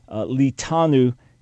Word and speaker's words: Litanu: Litanu is how it would have been pronounced in all probability in Ugaritic, Leviathan in Hebrew, speaking of a twisting, coiled serpent, just like Satan is described as a serpent.